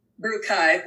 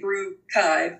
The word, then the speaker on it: Beerchive: It's Beerchive? – Beerchive, but that's okay. That's a lot of people say, because you see that C in, and yeah. So you just gotta say Beerchive and Beerchive, but no, it's Beerchive, like archive, brewing archive.